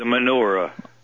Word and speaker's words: menorah: The menorah. – Oh, the menorah, okay. – Well, yes. – Same difference, but it's pronounced in the Yiddish, it's menorah.